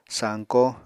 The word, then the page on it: sañkó: Apatani